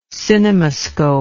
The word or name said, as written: cinemascope